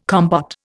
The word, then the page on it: compote: Indian